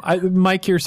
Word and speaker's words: Mykyr: You are outside of a planet called Mykyr. M-Y-K-Y-R, for all those fans who know how that's pronounced and didn't just read it. though I don't know that it's ever actually been said. – Mykyr sounds right to me.